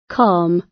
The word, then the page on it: calm: British English